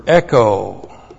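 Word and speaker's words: Echo (ἔχω): And then the verb echo. E-C-H long O. Echo.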